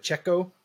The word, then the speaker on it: Pacheco: But you know who I think kind of took this one over is that, I'm going to butcher this, but Pacheco. Yep. Pacheco.